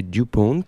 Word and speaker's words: DuPont: DuPont, prononcé DuPont.